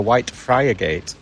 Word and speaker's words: Whitefriargate: When you're from Hull, it's Whitefrigate, isn't it? – Whitefrigate, yeah. – When you see it written down and visitors come and pronounce it correctly, they say we're looking for Whitefriargate. – That's right, yeah. But in Hull, it's Whitefrigate. – Whitefrigate.